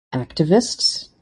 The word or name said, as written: activists